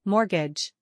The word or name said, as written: Mortgage